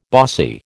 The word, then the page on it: bossy: American English